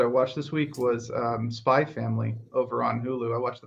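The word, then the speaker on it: Spy x Family: But like every anime fan that I know has been like, you have to watch this show, Spy Family. There's an X in the title, but I don't think you're supposed to pronounce it.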